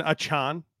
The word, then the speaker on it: Achane: You know, because I said I think I said Chain in like March and got corrected to A Chain, and now I've been saying A Chain, and he was like, actually, it's pronounced A Chan.